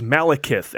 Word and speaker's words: Malekith: The villain this time around is Malekith.